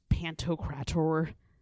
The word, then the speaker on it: Pantocrator: Well, okay, so it's sort of like, you know how there is like Christ Pantocrator? – Did you say that right? – I always have to say it like that. Yeah, look it up. That's exactly how you say it.